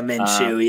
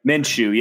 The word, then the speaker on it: Minshew: but Gardner Minshew, however you say his name. – Yeah, Minshew, yep. – Minshew, yeah.